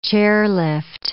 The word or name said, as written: chairlift